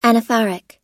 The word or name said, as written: anaphoric